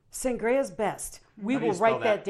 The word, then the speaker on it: Sangres: So it's Sangres. – Like a wine. Sangria. – Yes. Yes. – Not sangria. – Sangria? – Sangria.